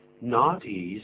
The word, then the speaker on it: naughty: Naughty 是怎么拼的呢？N-A-U-G-H-T-Y。 N-A-U-G-H-T-Y。 Naughty，请注意发音哦。 T 在这里念作 D， naughty。